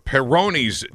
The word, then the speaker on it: Peyronie's: I believe it's pronounced Peyronie's disease. P-E-Y-R-O-N-I-E, or PD.